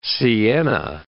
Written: sienna

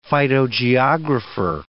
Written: phytogeographer

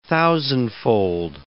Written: thousandfold

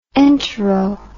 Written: entero

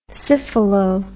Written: syphilo

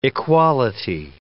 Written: equality

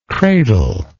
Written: cradler

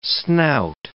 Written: snout

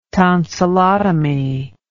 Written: tonsillotomy

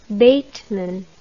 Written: bateman

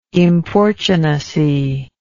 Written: importunacy

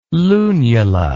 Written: lunula

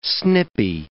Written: snippy

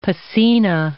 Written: piscina

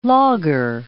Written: laager

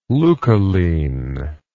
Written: leucoline

